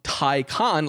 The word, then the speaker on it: Taycan: The whole campaign could just be to educate people on the correct pronunciation of Taycan, like TIE fighter.